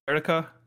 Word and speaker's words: Eureka: Actually, Eureka 7 is another one where I'm pronouncing it the way I think it's pronounced, but in the show, in Japanese, they call her name, uh, or they pronounce it Erika, so I guess it might be Erica Seven, but I see it, written down in English.